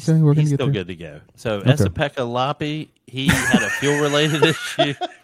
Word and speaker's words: Esa-Pekka Lappi: Esa-Pekka Lappi.